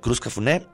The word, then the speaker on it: Cruz Cafuné: Además, estamos regalando también dos pases dobles para que usted se vaya a ver a Cruz Cafuné o Cruz Cafún, ¿cómo se pronuncia? Cruz Cafuné,